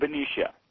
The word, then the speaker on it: Benicia: Benicia, California. Am I pronouncing that right? – In Benicia. – Benicia, okay.